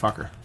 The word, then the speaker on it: Fokker: That is Roy Fuchiko. – It's pronounced Fokker.